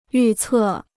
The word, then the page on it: 预测: yù cè